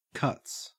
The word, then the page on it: cuts: Australian English